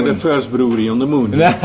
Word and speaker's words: Moen: Or is it M-O-E-N. – M-O-E-N. – Yeah, but it's pronounced like... Yeah, you pronounce it like moon. – I've always said Moen, so I'm glad that I know that. – It's just moon.